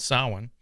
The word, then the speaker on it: Samhain: Technically it's not Halloween, it's called Samhain.